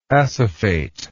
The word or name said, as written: acephate